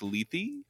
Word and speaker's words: Lethe: The, I think, Lethe is yes. – how you say it.